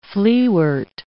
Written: fleawort

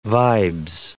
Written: vibes

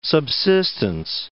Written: subsistence

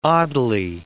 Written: oddly

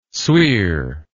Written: sweer

